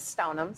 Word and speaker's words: Stoneham: In terms of following me, you can find me on LinkedIn, just my name, Krista Stoneham, spelled like Stone Ham, or on Instagram.